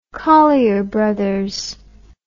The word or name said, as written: collyer brothers